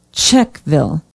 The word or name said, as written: Czechville